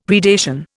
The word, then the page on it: predation: Indian